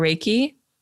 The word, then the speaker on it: Reiki: Reiki or Reiki? – Reiki, yes.